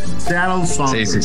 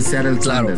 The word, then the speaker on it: Seattle Sounders: Me mama tu pronunciación. – Seattle Sounders. Sí. Seattle Sounders. – O sea, se ve que estudiaste en Harvard, ¿no? O sea, uno que estudió en escuela de numerito, pues tiene que decir Seattle Sanders, claro.